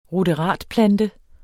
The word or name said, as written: RUDERATPLANTER